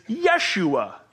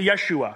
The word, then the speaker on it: Yeshua: The Hebrew pronunciation of Joshua is Yeshua. They didn't have the letter J in the Hebrew alphabet, so they had soft vowel sounds. Yeshua. Say Yeshua. Yeshua. Perfect.